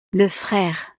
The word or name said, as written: le frere